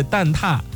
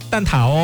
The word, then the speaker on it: 蛋挞: 哎，正确的读音是蛋挞，不读蛋挞哦。